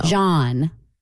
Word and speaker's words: jawn: J-A-W-N. Like yawn, but with a J in front of it. – Oh. – John.